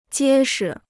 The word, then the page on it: 结实: jiē shi